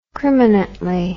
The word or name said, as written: crimanently